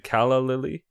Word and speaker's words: calla lily: Is it calla lily? – Uh-huh.